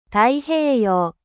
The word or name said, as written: ocean pacifique